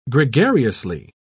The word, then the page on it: gregariously: British English